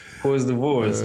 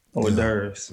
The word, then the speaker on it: hors d'oeuvres: Whore's divorce. – Man, I like that. Okay, whore's divorce. – Yeah. Hors d'oeuvres.